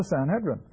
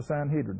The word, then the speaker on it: Sanhedrin: The Sanhedrin, or the Sanhedrin, however you want to pronounce it.